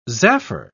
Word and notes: zaffer: British English

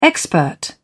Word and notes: expert: British English

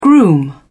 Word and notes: groom: British English

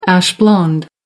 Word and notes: ash blonde: American English

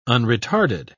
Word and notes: unretarded: British English

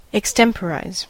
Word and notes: extemporise: American English

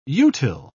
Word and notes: utile: British English

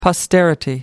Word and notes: posterity: American English

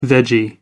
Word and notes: veggie: American English

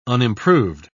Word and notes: unimproved: British English

